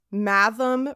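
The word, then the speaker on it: Mathom: He says, for example, Bulger has a G as in bulge, and Matham rhymes with fathom.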